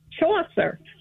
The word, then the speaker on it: Chaucer: Chaucer? – Chaucer.